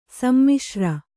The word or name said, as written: ಸೀಮೊಸರು